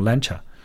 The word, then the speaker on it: Lancia: 就像我们刚才说的这个兰奇亚这个车的品牌，其实读成 Lancia。